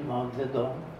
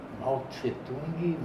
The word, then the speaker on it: Mao Zedong: Ficavam com os soviéticos, então eles gostavam era de Mao Zedong, para que eu pronunciei certo. Mao Tse-tung não existe. Mao Tse-tung é pronunciado errado. O nome dele é Mao Zedong.